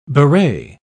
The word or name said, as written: beret